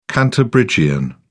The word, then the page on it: cantabrigian: British English